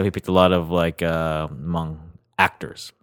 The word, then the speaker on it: Hmong: There's a large Hmong community, mm-hmm. H-M-O-N-G.